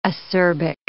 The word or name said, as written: acerbic